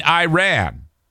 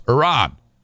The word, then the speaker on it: Iran: Adams is saying Iran, which is a very common mistake. That is not how it's pronounced. It's Iran. At least that's how I've said it. Iran, Iranian, Iran.